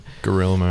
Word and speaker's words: Guillermo: Is it Guillermo or something? – Guillermo.